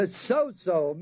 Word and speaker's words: sozo: S-A-V-E is the Greek word zoso, S-O-Z-O, long O's on both of them. To sozo means to save.